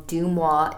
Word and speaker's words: Deuxmoi: So for those that don't know, Dumois is a Instagram account. – It's spelled D-E-U-X-M-O-I.